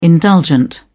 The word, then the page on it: indulgent: British English